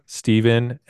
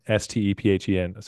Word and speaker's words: Stephen: 'Cause I have to be like Stephen Peyton. That's with a PH and an E. No, yeah, Stephen, Stephen.